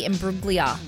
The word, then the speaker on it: Imbruglia: And Natalie Imbruglia for Torn. – Yep. – Did I say that right, Scotty? – Okay. – No G.